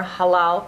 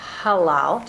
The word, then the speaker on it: halal: They require halal, which you will see more halal products. It is not a chet, it's a h, halal, just so you know how to pronounce it correctly, halal.